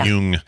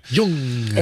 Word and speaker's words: Jung: Sorry, yeah. Jung. – Jung. – It's spelled J-U-N-G.